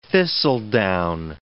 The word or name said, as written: thistledown